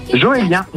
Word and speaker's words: Joélien: Joélien. J-O-E-L-I-E-N.